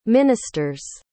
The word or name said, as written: minister's